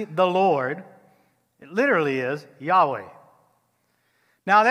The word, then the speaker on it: Yahweh: In the scriptures, it's often referred or said, pronounced Yahweh or Yahweh. You got to say it in Hebrew. Hebrew is a guttural language, so you got to put a little throat action into it.